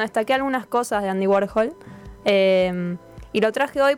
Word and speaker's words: Warhol: Ah, listo, Warhol, sí.